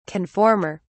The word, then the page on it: conformer: British English